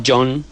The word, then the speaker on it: Joan: Aquí no se pronuncia Joan exactamente. Es una especie de John. – Ah. John, pero claro, John lo podrías confundir con John, que es... – De John. – El nombre exacto. – Con H intercalada y... – Exactamente. J-O-H-N.